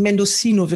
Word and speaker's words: Mendocino: Dann Mendocino, also wie das Lied, äh, deswegen ja. Mendocino wird da ausgesprochen.